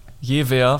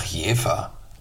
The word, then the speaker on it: Jever: Also es ist ganz dünnes Eis, auf dem ich mich hier ja, Du hast das V einfach seit jeher, jewer falsch ausgesprochen. Das heißt doch Jefer.